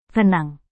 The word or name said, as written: renang